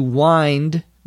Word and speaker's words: wind: Notice that W-I-N-D can also be pronounced wind. When it is pronounced wind instead of wind, it's a noun referring to air that moves quickly. Shanice then says, "Is there another bar stool?" A bar stool, one word, B-A-R-S-T-O-O-L, is a tall seat, usually that has a cushion on the top for you to sit on.